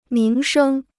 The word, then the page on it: 名声: míng shēng